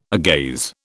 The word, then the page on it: agaze: British English